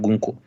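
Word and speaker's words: Гунька: Хунка. Ну, пишется как Хунка, да, да, но на самом деле там на русском языке это, конечно, Гуньку.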